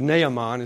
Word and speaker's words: Naaman: I say Naaman, but I think it's actually Naaman is the way it's pronounced.